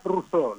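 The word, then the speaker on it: Rousaud: Mi apellido se pronuncia Rousseau, lo digo para Rousseau, perfecto, como el padre del liberalismo, Rousseau.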